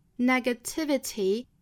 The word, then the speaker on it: negativity: I'm gonna take all 这个 take 和 all 连读成 take all this 注意 th 舌头伸出来， negativity 注意这几个音节哈， negativity 五个音节重读是在 t 这个位置上，